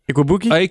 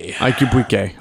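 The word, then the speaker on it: Igwebuike: Godwin Ikwabuki? – Ikubuke. – Ikubuke. All right. I'll go with your pronunciation.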